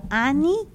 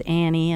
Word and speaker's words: Ani: You know, a little bit weird for the Americans to pronounce, you know, because everybody says Annie, and so it's a lifetime of correcting or not, just right.